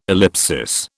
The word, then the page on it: ellipsis: British English